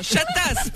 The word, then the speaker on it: Chatas: Non, Chatas, pas Chabat, Chatas. – Chabat. – Chatas. – Chatat, j'arrive pas à le prononcer. – Non, Chatas. – Un chat comme... Non, un chat comme un chat, oui. Et une tasse comme une tasse pour boire le café.